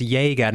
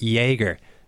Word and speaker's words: Jaeger: I was told by Killian at the time, I've looked up the emails, it's pronounced, make sure it's pronounced Jaeger, not Jaeger, as in like Jaeger bomb.